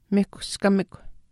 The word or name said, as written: mihkuskamikw